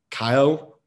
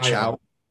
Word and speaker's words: Caio: Caio, not Chow.